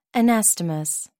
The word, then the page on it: anastomose: American English